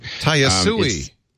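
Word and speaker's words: Tayasui: And have you used Taiyasu Sketches? I don't know if I'm saying it correctly. – Taiyasui. – Taiyasui Sketches.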